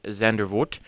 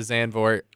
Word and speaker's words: Zandvoort: I thought we should start that out before we get into Zandvoort. – It's our one-year. – Pronounce it like Sandfort, but with a Z in front of it.